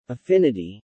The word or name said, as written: affinity